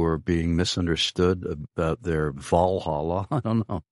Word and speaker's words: Valhalla: Valhalla, but it turned into Walhalla over here. – Yeah, good guess on my part, because Scandinavians pronounce their V's with W's.